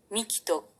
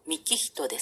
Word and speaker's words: 幹人: そんな、ミキト君って言いましたっけ僕の名前はミキヒトですよ。ミキトと書いて幹人です。